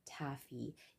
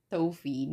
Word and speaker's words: taffy: You have so many flavors to choose from, and my personal favorite is English Taffy. Yes, it's pronounced as taffy and not Tofi.